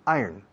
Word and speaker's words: iron: The pronunciation, se escribe iron, se pronuncia iron.